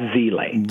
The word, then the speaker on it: Zeile: Okay, so one guy, his last name is Z-I-L-E. That's Zeile. – Zeile, okay. – Z-E-I-L-E is Zeile. Z-E-I-L-I-S is Zeilis.